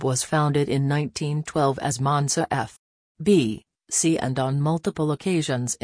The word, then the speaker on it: Monza: Associazione Calcio Monza, Italian pronunciation, Monza, listen, is a professional football club that is based in Monza, Lombardy, Italy.